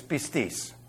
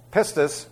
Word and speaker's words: pistis: It's pistis, all right? Yeah, pronounce it correctly.